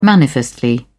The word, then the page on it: manifestly: British English